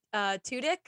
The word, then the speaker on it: Tudyk: So Alan Tudyk, am I saying that right? – Yes.